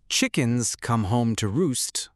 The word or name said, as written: chickens come home to roost